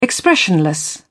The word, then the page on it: expressionless: British English